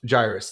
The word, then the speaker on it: Jairus: Like, there's crowds pushing all around him, and the Jairus, however you say his name, comes out. Jairus, there we go.